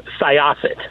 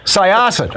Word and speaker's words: Syosset: Soyoset, New York. Am I saying that right? – Close. Syosset. – Syosset.